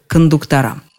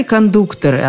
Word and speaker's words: кондуктора: Мне и самой трудно в это поверить, но орфоэпические словари на первое место ставят вариант кондуктора. Именно так поступает, например, словарь ударений Агеенко и Зарвы – Вернее, там есть и кондуктора, и кондукторы, однако между ними проводятся различия.